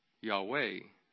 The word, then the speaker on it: Yahweh: In the JPS commentary, it says, Yod Hey Vav Hey was probably pronounced Yahweh in biblical times. The Vav was pronounced like the English W.